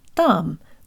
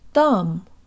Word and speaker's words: там: Там. Там. The te is pronounced with the part of the tongue just behind the tip. That's the portion that makes contact with the roof of the mouth.